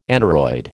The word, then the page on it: aneroid: American English